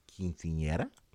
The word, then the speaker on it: quinceañera: So, you're going to Dallas in April, and I want to know about the origins of the quinceañera.